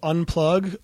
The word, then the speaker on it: Onplug: Yeah, if anybody's going to Panama City, go to a bar called Unplug, but it's spelled Onplug, O-N-P-L-U-G.